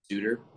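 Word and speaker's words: Shedeur: Shooter, shooter, shooter. That's how they say it.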